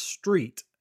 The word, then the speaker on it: Street: Well, ours is Street, and every time we say Ryan Street, they go, "How do you spell that?" And I'm like, "You know, like the road you drive on."